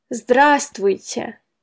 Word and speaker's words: здравствуйте: Первое слово – «здравствуйте». Оно пишется здравствуйте, но произносить можно проще. Здравствуйте. Здравствуйте.